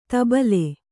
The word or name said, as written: ಧುಬಲಿ